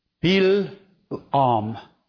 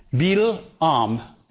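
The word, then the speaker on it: Bil'am: Okay, that's basically what that word Bil-Am is, how you pronounce his name.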